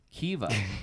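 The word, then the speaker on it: Caoimhe: Kiva. – Yeah. – So this is the next one. – If that's an M, makes it a V.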